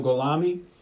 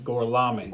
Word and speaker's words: Gorlami: Enzo Golami. – I'm sorry, how do you say that? – Gorlami.